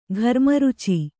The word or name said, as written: ಖರಮರೀಚಿ